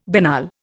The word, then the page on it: banal: Indian